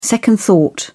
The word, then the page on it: second thought: British English